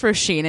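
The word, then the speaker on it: Róisín: But she writes a little note and says, it's pronounced Roisin, in case you didn't know, although your Sinead's and Siobhan's were very impressive.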